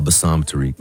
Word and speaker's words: Bassam Tariq: Bassam Tariq.